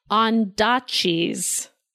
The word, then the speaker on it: Ondaatje: But I completely mispronounced Michael Ondaatje's name. – Okay. – That is how you say it.